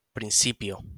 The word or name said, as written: principio